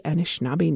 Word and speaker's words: Anishinaabe: Anishinaabe Nation. Our pleasure to welcome him in. Hello. – Hello, and may I just say, great job on the Anishinaabe Algonquin pronunciation there. You're a natural.